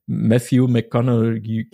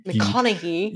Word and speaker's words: Matthew McConaughey: Matthew McConaughey. – McConaughey.